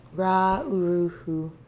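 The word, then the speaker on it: Ra Uru Hu: And we're talking about Rakuru. Ra-uru-hu. Butchered that, I'm sure. – Ra-uru-hu. Sounds about right.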